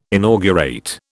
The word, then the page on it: inaugurate: British English